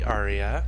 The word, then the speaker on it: Aria: So I'm now legally female and legally Aria. – What did you legally Aria? – Aria is my legal first name. – Oh, how do you spell that? – A-R, like Maria without the M.